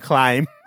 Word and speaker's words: climb: why'd you pronounce the B in climb? – Oh, why did I do that? Climb.